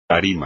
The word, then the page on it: tarima: espagnol